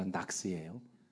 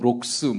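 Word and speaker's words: Knox: 그분이 왁스가 아니라 낙스예요. 락스가 낙스도 아니고 영국 발음은 록스 뭐 이렇게 말하는데 뭐 그냥 낙스나 록스나 그 보람이 다 그거고.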